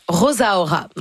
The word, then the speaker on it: Rosaura: Rosaura, où elle, elle prononce Rosaura.